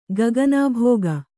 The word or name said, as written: ಗಗನಾಭೋಗ